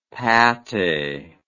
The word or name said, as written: pattee